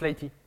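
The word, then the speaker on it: lighttpd: Ça se prononce Lighty.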